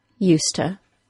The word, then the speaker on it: used to: In connected speech, however, the word to is reduced to ta, and we have the complete modification of the phrase used to the common, current, yet informal pronunciation of useta.